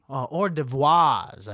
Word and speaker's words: hors d'oeuvres: Maybe Zack Snyder will get the hors d'oeuvres ready for you when you come in. – Hors d'oeuvres. – Hors d'oeuvres, I believe is how it's said.